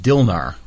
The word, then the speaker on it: DILNAR: I guess it's pronounced Dilnar, right? – Mm-hmm. – D-I-L-N-A-R, and that stands for driving in the left lane, no apparent reason.